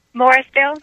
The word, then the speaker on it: Morrisville: Morrisville.